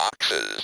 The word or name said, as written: ox's